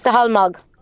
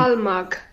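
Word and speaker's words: Staholmog: Stahalmug. Um, that name is pronounced as Stahalmog. Oh, go on. Have you another one there for me? We'll be listening. – Stahalmog.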